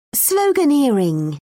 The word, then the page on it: sloganeering: British English